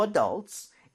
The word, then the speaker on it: adult: A-D-U-L-T 성인이라는 단어죠. 근데 강세를 좀 주의할 필요가 있습니다. 어디 갈것 같아요? – Adults. – Exactly. 자, 이렇게 발음해 보도록 할게요. 유에다 강세를 많이 주면서 adult, adult.